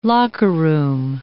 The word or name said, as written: locker-room